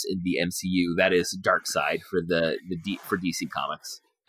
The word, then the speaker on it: Darkseid: who I believe is pronounced Darkseid. I remember long debates as to whether this was right. Darkseid or Darkseid, because it's spelled Darkseid.